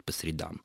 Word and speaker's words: по средам: Допустим, эти занятия проходят по средам. Еще раз, Галина Николаевна, защищен ту точку зрения, которая прозвучала уже в одной из предыдущих программ, в современном русском языке традиционно правильным все-таки будет ударение по средам.